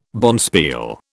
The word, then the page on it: bonspiel: British English